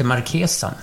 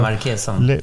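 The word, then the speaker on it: Marchesan: Har du någonsin varit i Vasastan och träffat Alexander Marchisan? – Ja, säger man inte markesan? – Säger man så? Jag kan inte, ja, förlåt. Jag kanske förolämpar honom nu. Jag är väldigt dålig på uttal. Och det är markesan. – Bra, du har rätt.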